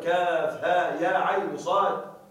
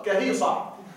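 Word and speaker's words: كهيعص: أنا أذكر كنت أقرأ قوله تعالى في أول سورة مريم كاف ها يا عين صاد، كنت أقرأه على أنها كلمة، كنت أقرأ كهيصة، وأنت لو نظرت وأنا ما أدري أن هذه الحروف تنطق هكذا.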